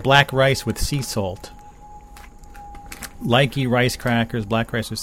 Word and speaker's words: Laiki: Lakey, L-A-I-K-I. I guess you can pronounce it Likey or Lakey. Likey. I like these crackers, but I prefer to pronounce it Lakey. Do they have a pronunciation? They do have a pronunciation. L-Y hyphen K-E-Y. Likey. Likey. Okay, so it's supposed to be Likey, not Lakey.